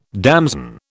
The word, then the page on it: damson: British English